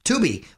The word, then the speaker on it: Tubi: Tubi.